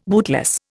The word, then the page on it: bootless: Indian